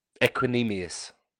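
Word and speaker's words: Equanimeous: Equinemius.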